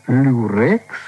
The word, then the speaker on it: люрекс: как сейчас некоторые женщины предпочитают люрекс. Не знаю, правильно ударение делаю? – Люрекс. – Люрекс.